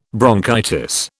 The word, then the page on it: bronchitis: British English